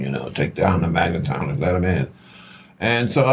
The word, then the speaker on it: magnetometers: You know, take down the megatrometers." I think that's, I'm saying that right. – Magnetometers. – Magnetometers. Yes. Thank you, big bro.